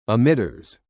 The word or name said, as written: emitters